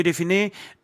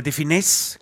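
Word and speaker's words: de Funès: Und Louis de Finesse, oder de Finesse, glaube ich. – De Finesse wird es ausgesprochen.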